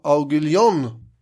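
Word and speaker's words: Aiguillon: Det är först när hertigen av Aguillon... – Perfekt uttal.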